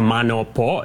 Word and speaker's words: Mano Po: so Mano Po is the pronunciation.